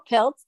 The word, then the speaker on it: Pilz: It's April Pilz. That's spelled P-I-L-Z-L-M-T.